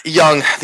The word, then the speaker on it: Younge: Young.